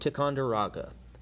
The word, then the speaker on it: Ticonderoga: One of his most well-known victories was at the Battle of Ticonderoga.